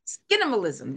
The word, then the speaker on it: Skinimalism: But one of the other things, skin minimalism. Am I saying that correctly? Skinimalism. There we go.